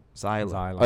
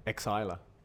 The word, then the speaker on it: Xyla: So the spelling's X Y L A. – Yeah. Zyla. Like a silent X. – Yeah. – Exyla.